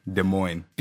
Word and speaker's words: Des Moines: Des Moines, Iowa. There you go. No S's there. No S pronunciation there. Des Moines.